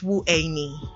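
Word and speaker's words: Wu Ei Ni (我爱你): Yeah, Wu Er Lin is 520 and I love you is Wu Ei Ni.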